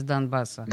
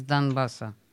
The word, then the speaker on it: Донбасса: Правильно говорить, да? Из Донбасса. Да, да. Или из Донбасса.